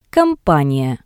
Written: companiya

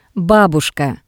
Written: Babushka

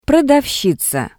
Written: prodavshiza

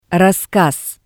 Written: rasskas

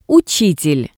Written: utsitel

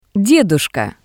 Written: Dedushka

